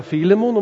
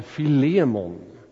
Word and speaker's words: Filemon: Och du får uttala namnet Filemon lite som du vill. Man kan säga Filemon om man tycker det ligger bättre i munnen. Jag har lärt mig att uttala det Filemon.